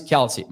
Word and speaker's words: Kelce: Thank you for the correction. Travis Kelce. My bad.